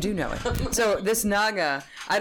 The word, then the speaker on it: Naga: That's Nagas. – Nagas. Am I mispronouncing it? – Yes. – What? Naha? – It's Naga. – Naga. – N-A-G. – Oh, like, they spelt right. It with a J, so then I pronounced it like an H.